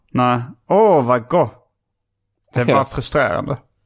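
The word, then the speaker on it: GWOH!: Eller jag via Bromander har fått reda på att alltså det står G-O-O-H. G-W-O-H utropstecken. Ja, och det är inte de frågade hur skulle de frågade grundaren hur det skulle uttalas för det var många som uttalade go eller go. Men han sa att det skulle vara som att det är så on the go. – Men han menade att det skulle låta som gott fast bara go.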